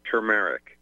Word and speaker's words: turmeric: Well, it's T-U-R, turmeric. Or some turmeric. – People say, yeah, turmeric. Yeah, not T-U-M, it's T-U-R.